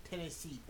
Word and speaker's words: Tennessy: If you look for him, if you're trying to find him on SoundCloud, it's under Tennessy. That's like Hennessy spelled like Hennessy with a T in front.